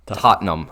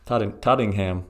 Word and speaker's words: Tottenham: Or as some people pronounce it. – You just pronounced it that way. – I was fighting the urge. – Tottenham. – Tottenham. Tottingham. – Yep, Tottingham. Tottenham. Tottenham.